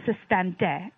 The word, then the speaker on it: système D: The first tactic they employed became known as système D in French pronunciation, or system D.